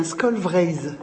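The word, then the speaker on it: Skol Vreizh: Alors, aux éditions, je ne sais pas comment ça se prononce exactement, Skolvreiz. – Oui, c'est très bien, Skolvreiz.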